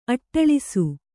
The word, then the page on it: ಅಟ್ಟಳಿಸು: kannada